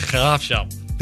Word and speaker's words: Graafschap: The Graafschap. – There you go. – The Graafschap.